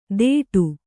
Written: ಥೇಟು

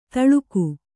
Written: ಥಳುಕು

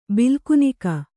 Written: ಬಿಲ್ಕುನಿಕ

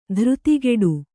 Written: ಧೀರತೆಗಿಡು

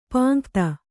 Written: ಪಾಂಕ್ತ

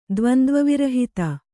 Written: ದ್ವಂದ್ವವಿರಹಿತ

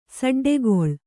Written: ಸಡ್ಡುಗೊಳ್ಳು

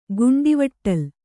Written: ಗುಂಡವಟ್ಟಲ್

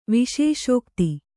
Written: ವಿಶೇಷೋಕ್ತಿ

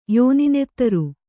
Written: ಯೋನಿನೆತ್ತರು

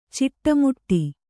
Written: ಚಿಟ್ಟೆಮುಟ್ಟಿ

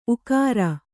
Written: ಊಕಾರ

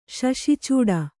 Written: ಶಶಿಚೂಡ

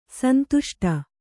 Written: ಸಂದಷ್ಟ

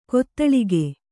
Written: ಘಾತಾಳಿಕೆ

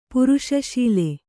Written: ಪುರುಷಶೀಲೆ

